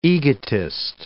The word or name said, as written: egotist